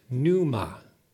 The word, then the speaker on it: pneuma: And it's related to the Greek word for spirit, pneuma, where the P is silent.